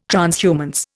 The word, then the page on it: transhumance: Indian